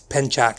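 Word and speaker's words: Pencak: Ever say his name clearly, but at the end they I believe in the language it's Ucho, because the martial art is spelled Pensak, and it's Penchak, so I'm guessing the C makes a ch sound in Indonesian.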